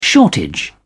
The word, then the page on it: shortage: British English